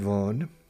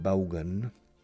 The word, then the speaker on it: Vaughn: My name's Richard Vaughn, Richard Baugan. Vaughn is the pronunciation. The correct pronunciation of my last name is Vaughn, con V, que suena V inglesa, que es como una F vibrada, Fawn.